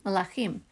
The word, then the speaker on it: melachim: The vowelization tells us to pronounce the word for kings, melachim, mem, lamed, chaf.